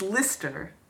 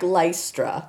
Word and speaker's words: Lystra: We live near... it's Lister. – But it looks like Lystra. – Yes, it's spelled Lystra. This is a problem for me. – Yes, but they but we've been told that it's Lister.